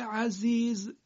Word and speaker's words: عزیز: کلمه عزیز زش زی فارسیه، یعنی همون جوری که ما ز رو در فارسی تلفظ میکنیم اینجا هم کلمه عزیز زش باید همون جوری تلفظ بشه.